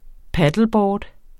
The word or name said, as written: paddleboard